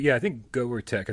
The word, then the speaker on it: GoerTek: I think GoerTek. I think you divide the syllables there.